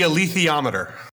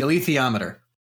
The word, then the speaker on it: alethiometer: The, and I may be pronouncing this wrong because I've never heard it out loud, but the alethiometer from basically the titular Golden Compass. – It's the alethiometer. – Thank you. The alethiometer.